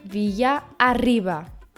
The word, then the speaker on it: Villarriba: Folgen zwei Rs hintereinander, so wird das R stark ausgesprochen. Wie zum Beispiel Villa arriba.